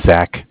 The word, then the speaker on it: Zach: Okay, my name is Zach, spelled Z-A-C-H.